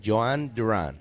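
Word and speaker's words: Jhon Durán: It's a soft J, so it's Joanne. – Soft J, Joanne Duran.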